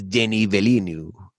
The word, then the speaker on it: Denis Villeneuve: It's Denis Villeneuve.